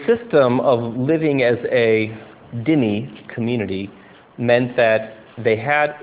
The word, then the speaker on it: dhimmi: You know, for many centuries, the Christians in the Middle East lived as dhimmi, or this is usually in English pronounced dhimmi. The word is D-H-I-M-M-I, that's the spelling.